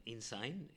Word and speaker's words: Insein: So it's spelled I-N-S-E-I-N, but insane is the name, and it pretty describes the place.